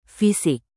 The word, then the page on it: fisik: indonesian